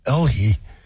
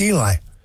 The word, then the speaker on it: Eli: Yeah, it's Eli with an H. And that's what gets me. I was wanting to make sure I was saying it right, because Elhi. But it's Eli.